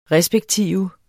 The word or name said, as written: respektive